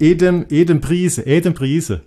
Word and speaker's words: Adam Price: Wie spricht man Adam Price? – Du, ich glaube, du darfst den Adam Price aussprechen. Die haben ja. – Das nee, das ist kein Engländer, das ist ein Däne. – Ist ein Däne, aber ja, ich glaube, du darfst ihn trotzdem Adam Price aussprechen. Ja, der spricht sich Adam Price. – Ist das echt so?